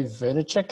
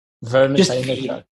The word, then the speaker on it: Vaynerchuk: I think I was listening to Gary Vaynerchuk. How do you pronounce Gary V's? – Vaynerchuk. – Vaynerchuk.